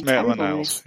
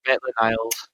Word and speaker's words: Maitland-Niles: I think Maitland-Niles, is that how you pronounce it? Niles, Giles. I can't read my own writing, listeners. I'm really sorry. – Yes, Maitland-Niles. – Maitland-Niles.